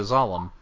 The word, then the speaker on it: Zalem: Oh, by the way, I googled it, you're right, it's spelled Z-A-L-E-M, so Zalem, not sure. – I just pronounced it how I would Salem, like Salem, Massachusetts, with the S instead of, or with a Z rather than an S.